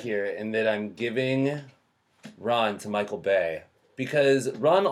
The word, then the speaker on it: Ran: Ron is, I think, more appropriate.